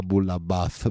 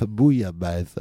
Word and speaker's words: bouillabaisse: imaginons qu'on doit cuisiner quelque chose de typique français, mais je dirais plutôt marseillais, qui s'appelle bouillabaisse, pas boulabaisse, bouillabaisse, ok.